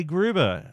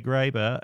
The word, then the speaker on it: Graber: Please enjoy it, despite the fact that I keep pronouncing the name of Bluesky's CEO. Uh, Jay Graber as Jay Gruber. Uh, she is Jay Graber, and I am an idiot.